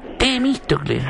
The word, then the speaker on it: Temístocles: Temístocles. – Sí, no, yo no encontré nada. – Es con acento en la I, es una palabra esdrújula.